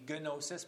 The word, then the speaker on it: gnosis: I guess it would be gnosis, because you pronounce the diphthongs, gnosis, from which we get the word Gnostic.